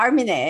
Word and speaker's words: Armineh: Yeah, Armineh, exactly, Keshishian.